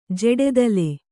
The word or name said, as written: ಜಡೆದಲೆ